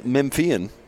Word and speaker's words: Memphian: He's from the Daily Memphian. You think I'm saying that right, by the way? – I think, yeah. – It's like Memphis, but with an I-A-N.